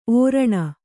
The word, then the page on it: ಓರಣ: ōraṇa